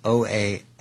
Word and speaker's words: Oaxaca: In this particular province, it's OAXACA. I don't think I've ever heard that said. How do you say that? – Oaxaca.